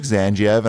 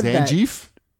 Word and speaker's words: Zangief: Okay, he said Zangief? – That. – Zangief is what I always pronounced it. But I don't know. – Well, you and I say it the same way.